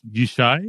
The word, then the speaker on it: Yishai: Yishai? – Yes.